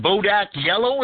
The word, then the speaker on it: Bodak Yellow: Yeah, that Bodak Yellow, is it? – Yeah.